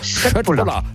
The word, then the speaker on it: Köttbullar: Ja, Köttbullar. – Ja! Oder wenn man es ganz korrekt aussprechen, ich habe mir sagen lassen, das K wird in Schweden wie ein Sch ausgesprochen. Also wenn es Schöttbullar, hat es doch geklappt.